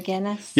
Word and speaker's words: Guinness: So since you've been doing cakes, I thought about a chocolate Guinness cake. Is that how you say it? The beer? The Guinness? – Yeah.